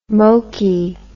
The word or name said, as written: mokey